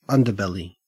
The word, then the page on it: underbelly: Australian English